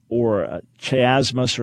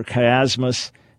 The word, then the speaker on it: chiasmus: So there's something called chiastic structure or chiasmus or chiasmus, as different pronunciations have it.